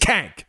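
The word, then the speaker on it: Cenk: But it's pronounced, I get how he's Kank on this show. He's Kank. If you want to go listen to his show and hear his, fine, but on this show, it's Kank and the Kankster Curve, right? – Yeah.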